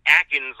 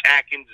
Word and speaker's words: Akins: Not Atkins like the Atkins diet. There's no T in there, but it's Atkins. It's spelled like Akins.